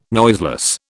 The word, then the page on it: noiseless: British English